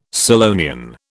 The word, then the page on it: Scillonian: British English